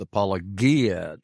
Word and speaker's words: Apologia: And it was allegedly, according to a New Testament Greek scholar like Dr. White, it was supposed to be pronounced Apologia Church.